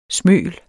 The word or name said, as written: smøl